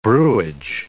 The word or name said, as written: brewage